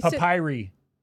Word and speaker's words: papyri: And the reason for that is they have over 1 million books, parchments, and papyruses. Or I said that wrong. – Yeah, you papyri. Papyri. – Well, look at you. – I think it's called papyri.